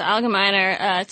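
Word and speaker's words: Algemeiner: Allgemeiner? – Yes, the Allgemeiner, top 100 people positively influencing Jewish life list.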